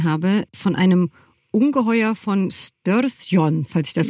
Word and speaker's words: Storsjön: Äh, und der See heißt Storsjön.